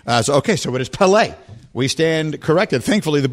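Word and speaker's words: Pelé: It's Pele. – On the second syllable.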